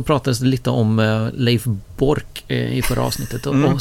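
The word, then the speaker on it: Boork: Leif Bork, uttalas Bork, är tränare för damernas landslag i hockey.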